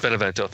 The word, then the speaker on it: Benevento: And I like Marco Benvenuto. I think I said his name right. The keyboard player. – Benevento, thank you.